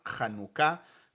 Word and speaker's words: Hanukkah: Sí, entonces en el hebreo esa palabra tiene el énfasis en la última sílaba, lo que llamaríamos una palabra aguda en el español, Hanukkah.